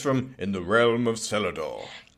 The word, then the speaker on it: Celador: Celador? – No, it's Celador, with a hard C, from the Celtic, darling.